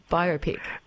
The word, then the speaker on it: biopic: How do I pronounce it first up? Biopic or biopic? – Biopic.